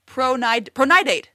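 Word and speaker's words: Procyonidae: Pronidate. That's how you say it.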